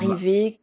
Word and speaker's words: Marivé: Marivé comme arrivé en créole, donc Marivé.